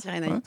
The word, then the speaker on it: Cyrénaïque: Cyrénaïque, c'est comme ça que ça se prononce ? – Cyrénaïque.